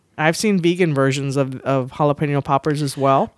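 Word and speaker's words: jalapeño: Jalapeno poppers. And that was white. – At least you didn't pronounce the J.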